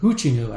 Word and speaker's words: Hoochinoo: But hooch, the term hooch is actually short for hoochinoo. – How do you spell that? – H-O-O-C-H-I-N-O-O.